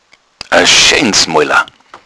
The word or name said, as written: moila